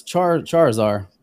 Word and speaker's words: Charizard: Is it char lizarding? Char izarding is what the name of it is. That's right. It's a char lizard. – Because it's Charizard.